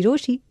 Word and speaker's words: Rochie: मेरा नाम है मिर्ची रोशी, नाम की स्पेलिंग है ROCHIE, और मेरे साथ लाइन पर अभी है कमल.